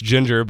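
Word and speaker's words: Jinjer: I know it's pronounced Ginger, but it's spelled with two J's.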